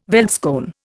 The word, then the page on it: veldskoen: Indian